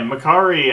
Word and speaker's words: Makkari: And uh, another character, uh, I won't say Makkari. That's how you pronounce it, right? Yeah, Makkari. I was one of my favorites.